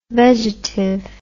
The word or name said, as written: vegetive